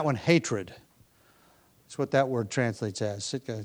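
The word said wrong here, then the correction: Sitnah: translates as hatred. Or Sitna? I pronounced it incorrectly. Sitna translates as hatred.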